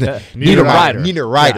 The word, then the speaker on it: Niederreiter: You know, Nino Niederreiter. Did I pronounce it? – Niederreiter, yeah.